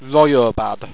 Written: royobad

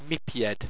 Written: mipyed

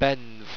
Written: benve

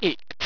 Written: ickt

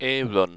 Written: ayrun

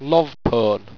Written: lovpone

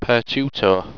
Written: pertutore

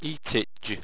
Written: eatitg

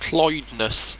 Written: cloidness

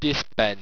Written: disben

